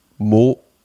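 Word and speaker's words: Moët: Also, wie spricht man es richtig aus? Alle, die Sprezzatura hören, wissen das ab jetzt. Es ist Moet.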